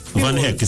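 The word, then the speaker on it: Van Hecke: Van Hek. It's just Van Hek.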